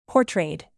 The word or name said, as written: portrayed